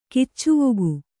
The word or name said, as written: ಕಿಚ್ಚುವೊಗು